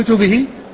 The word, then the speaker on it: كتبه: ففي قراءة كتبه تكون الكاف مضمومة والتاء مضمومة والباء مكسورة، وكتابه تكون الكاف مكسورة وال يعني فيكون الاختلاف انما هو يعني في النطق لا في الرسم، واما الرسم يشمل.